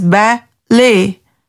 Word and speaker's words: ballet: Like in ballet, it is pronounced as ba-lay.